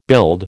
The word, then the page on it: build: American English